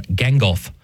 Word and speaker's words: Gangolf: And yes, it's spelled very much like the wizard from The Lord of the Rings, but this is G-A-N-G-O-L-F. So G-A-N and then golf, Gengulf.